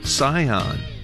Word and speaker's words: Sihon: Sihon, S-I-H-O-N, Sihon 이렇게 발음해 주시고 그모스는 C-H-E-M-O-S-H입니다.